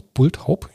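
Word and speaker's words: Bulthaup: Ähm, Bulltaub. Spricht man die so aus? Bulltaub? Ja, ne? – Wie sollte man es sonst aussprechen?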